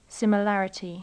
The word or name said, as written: similarity